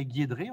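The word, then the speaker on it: GiedRé: Et en avant-première, il y avait une personne qu'on ne connaissait pas qui s'appelait Guédré, on prononce comme ça.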